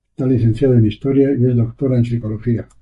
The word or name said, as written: doctora